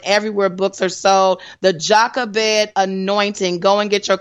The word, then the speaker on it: Jochebed: All right, so Jochebed, that's what it, how you pronounce it. And so Jochebed is the mother of Aaron, Miriam and Moses, just so everybody knows. She is the daughter of a Levi. So just wanted to let you know that. I did say it right, Jochebed.